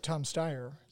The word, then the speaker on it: Steyer: Well, you got Tom Steyer.